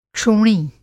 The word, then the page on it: क्षोणी: Hindi